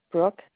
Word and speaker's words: Brooke: Brooke Stamm, STAMM.